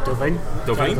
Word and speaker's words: Delevingne: How do you pronounce it? – Delevingne. – Is that how you pronounce it? – Yeah. – Are you sure on this? – There's no A in it, it's just Delevingne.